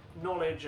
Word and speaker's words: knowledge: Pneumatic, knowledge, and gnome. Oh, I think that was just the Big Nerd alert. – Silent letters. – They have silent letters at the beginning, and that, I'm afraid, is the end of round one with a triumphant 3-0 score to Big Nerd.